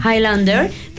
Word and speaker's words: Highlander: Highlander, protagonizada por Christopher Lambert y Sean Connery.